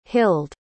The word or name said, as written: hild